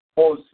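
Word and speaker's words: Hozeh: Seer is used there as well, but in this particular case, it is pronounced Hose, and you find 2 Samuel chapter 24, verse 11.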